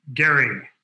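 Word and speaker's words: Gerry: with Randolph and Mason and Gary, as I've learned pedantically to pronounce it,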